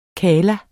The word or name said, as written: kalla